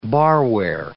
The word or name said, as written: barware